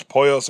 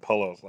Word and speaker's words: Pollos: Now, then Mike sends Jimmy to Los Pollos Hermanos. – Mm-hmm. – I think I pronounced it Pollos last time, but it's Pollos.